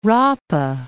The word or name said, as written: Rapa